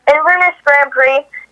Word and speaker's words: Inverness Grand Prix: Inverness Grand Prix, Florida? – Yes, Inverness Grand Prix.